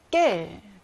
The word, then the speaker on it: quel: 자, 깰은 의문 형용사 깰, 그리고 여성 단수형 깰, 남성 복수형 깰, 여성 복수형 또 깰이죠. 네 개가 발음이 다 똑같아요. 깰, 깰, 깰, 깰다 똑같습니다.